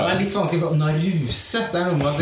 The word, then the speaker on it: Naruse: Egentlig eh, uttales det Naruse? – Naruse. – Okay. Akkurat som Det heter ikke Kurosawa, men Kurosawa.